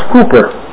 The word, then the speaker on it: Cowper: His name is spelled C-O-W-P-E-R, but pronounced Cooper.